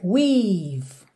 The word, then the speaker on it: we've: Aqui Muita atenção a essas letrinhas E que estão pintadas de cinza porque você realmente não pronuncia. Percebe que é weave. Você fecha o som no V.